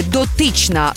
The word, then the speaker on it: дотична: Наголос на другому складі «дотична» значення таке.